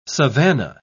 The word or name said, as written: savannah